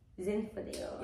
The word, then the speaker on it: Zinfandel: Zinfandel.